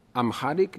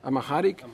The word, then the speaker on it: Amharic: Amharic version, or Amharic, is a language? Amharic.